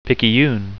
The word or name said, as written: picayune